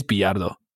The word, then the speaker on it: Bilardo: And Bilardo, it's Bilardo. Hey, Bilardo.